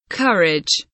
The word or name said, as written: courage